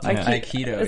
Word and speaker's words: Aikido: She's doing a little Aikido. – Aikido. – Aikido, is that how you say it? – Yeah.